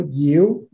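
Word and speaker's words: dịu: Donc, il prononce le mot Diu, mais ça s'écrit D-I-U, ça veut dire doux.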